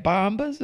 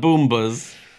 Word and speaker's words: Bombas: Is this week's episode sponsored by, is how do you say it, Bombas or the Boombas? – Certainly not Boombas.